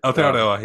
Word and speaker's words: Aotearoa: Did I even pronounce that right? – Aotearoa, yeah, you did.